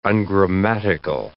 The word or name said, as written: ungrammatical